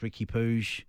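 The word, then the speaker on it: Riqui Puig: Ricky Puig, that's how you pronounce it. I think we trust Albert Ferreira's knowledgeable on Catalan pronunciations.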